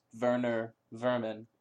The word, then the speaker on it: Werner Werman: I like how it's spelled Werner Werman, but he's German, so it's Werner Werman.